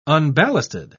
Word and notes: unballasted: British English

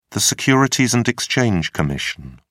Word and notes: Securities and Exchange Commission: British English

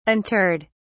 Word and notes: interred: British English

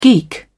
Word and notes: geek: British English

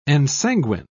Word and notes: ensanguine: British English

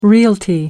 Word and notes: realty: American English

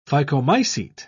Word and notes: phycomycete: British English

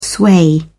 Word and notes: sway: British English

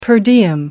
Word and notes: per diem: American English

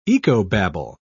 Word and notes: ecobabble: British English

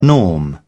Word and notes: norm: British English

